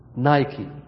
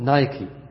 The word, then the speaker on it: Nike: الآن في شركة عالمية لها ثنايا وكنادر وشرابات بل شنط، NIKE وش هي؟ نايكي، هكذا تنطق نايكي، NIKE، وترجمتها آلهة النصر عند الإغريق.